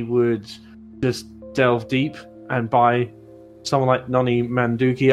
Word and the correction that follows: Madueke: Noni Madueke.